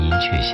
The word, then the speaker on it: 寅恪: 就这样，陈寅恪终于打下了属于自己的一片天空。我们再来看看柳青的文章《陈寅恪为学术的一生》当中的一段描述吧。 – 当年清华上下都叫他陈寅恪先生，然而在不少字典里没有雀这样的读音，有人请教他，为什么大家都叫你寅恪，你却不予纠正呢？